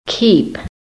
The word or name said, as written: KEEP